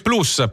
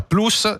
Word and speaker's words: plus: Anche plus. Plus, che in latino vuol dire anche lui più, si pronuncia plus, vi stupirà sapere. – Non plus. – Non plus, non dite plus.